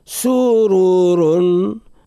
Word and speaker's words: سرور: سرور حرف الراء هو المفخ وهو متكرر في الكلمة. سرور سرور نفخم صوت الراء هذا على مستوى التلفظ بالحروف. سرور الراء الثانية من كلمة سرور منونة بتنوين الضم، والتنوين كلما نجد حرف منون في آخر الكلمة لابد له قاعدة نطقية لابد.